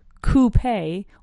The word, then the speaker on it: coupe: Then you can have a coupe, and a coupe has two pronunciations, two valid pronunciations. You can say coupe or coupe, es decir, acentuando la U, coupe, or acentuando la E en coupe, right?